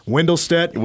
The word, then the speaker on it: Windelstedt: I'm going to go with MLB umpire Hunter Windelstedt. Am I saying that right? – Yes. – Windelstedt.